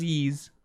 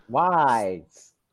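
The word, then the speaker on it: Ys: I think it's pronounced Yeez. – It's pronounced Yeez, but everybody calls it Wise. – Yeah. – Wise.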